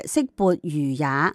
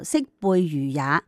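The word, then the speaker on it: 色勃如也: 有一次上课，小万历将《论语》当中的《色薄如也》读成了《色背如也》，张居正大喝一声，小万历惊到一下子从座位上边企咗起身，成身打冷震。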